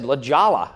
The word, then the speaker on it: La Jolla: Or my favorite gaffe was La Jolla. I tried to get a phone number in La Jolla, and the operator says, what city? I said, La Jolla.